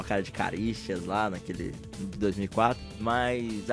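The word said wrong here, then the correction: Charisteas: Então, Caricias lá, que a gente tentou pra caralho pronunciar o nome, se chama Haricias.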